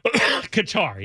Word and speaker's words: Qatar: Qatar, yes.